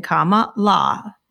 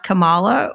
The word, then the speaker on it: Kamala: And again, for people that don't know, it's comma as in comma la, not Kamala, or how else would you pronounce that?